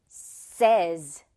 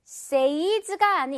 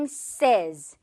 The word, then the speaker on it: says: 하지만 여기 지금 이렇게 S가 붙어있는 상황에서는 또 달라져요. 어떻게 달라지냐면 says 이렇게 발음이 된답니다. says가 아닌 그냥 says. 오케이? 특이하죠. 이게 특이하기 때문에 특수한 경우이기 때문에 꼭 기억을 해야 하는 거예요.